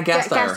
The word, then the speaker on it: Gasteyer: Anna, I can't say her name. Anna Gersher from Saturday Night Live. – Yes, Anna Gasteyer. – Gasteyer.